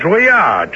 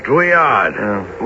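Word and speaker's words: Drouillard: Yeah, Druyard. George Druyard.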